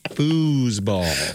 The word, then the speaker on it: foosball: You're putting the wrong emphasis on the wrong syllable. – Okay. – Foosball.